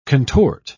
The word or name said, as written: contort